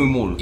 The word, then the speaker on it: Mulk: Ale to nie jest Milk, raczej się nie wymawia Milk. Milk. – Czyli Milk, tak? Takim, Milk. Z takim umlautem trochę. – Możemy tak to krócej, Milk. – Mówimy Mulk.